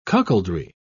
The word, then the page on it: cuckoldry: British English